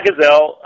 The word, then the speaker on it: Gazelle: You pronounce it Gazelle? – Yeah, Gazelle. G A Z E L dot W S.